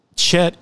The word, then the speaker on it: Chet: Well, the Chet is pronounced using the CH on bach. It is a guttural sound.